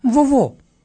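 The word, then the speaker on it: vovô: En portugués, abuelo se dice vovó, vovó, así con B chiquita y con acento en la segunda O.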